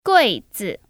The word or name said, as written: GuiZi